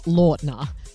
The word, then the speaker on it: Lautner: Okay, so we're saying Taylor Lautner, and in this interview, he says it's actually not Lautner. – It is technically Lautner. – What? – It is my fault. Nobody knows how it's actually pronounced because I never corrected anybody for decades. – No way. – I just never in interviews or anything stopped somebody and said, it's not Lautner, it's Lautner.